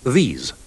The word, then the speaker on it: these: Sentirete che nella prima parola il suono della vocale è molto più breve. – This. These. – Inoltre la prima S si pronuncia come nella parola rosso, mentre la seconda ha il suono simile alla S di rosa.